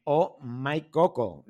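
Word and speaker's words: Oh My Koko: Nada, gracias a ti por atendernos, que vamos a aprender mucho contigo y con la experiencia que tenéis en Oh My Coco. ¿Lo he dicho bien? – Perfectamente, O como la interjección, ¿sí? – OH, My en inglés, mi y Coco, las dos con K.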